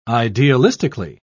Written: idealistically

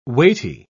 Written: weighty

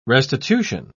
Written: restitution

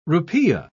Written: rupiah

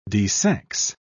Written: desex

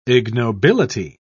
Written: ignobility

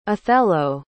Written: othello

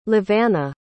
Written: levana

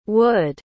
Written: wud

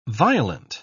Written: violent

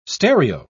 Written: stereo

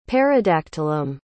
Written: paradactylum

